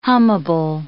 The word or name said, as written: hummable